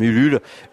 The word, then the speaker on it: Ulule: parce que c'est une campagne menée sur la plateforme Ulule, U-L-U-L-E, comme ça se prononce.